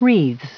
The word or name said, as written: wreaths